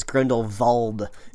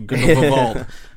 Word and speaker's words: Grindelwald: I don't think you're pronouncing that name right. They very emphatically say it's Grindelwald in the movie. There's a V in there somewhere.